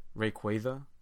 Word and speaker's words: Rayquaza: Rayquaza, like a quasar, because of space and the cosmos.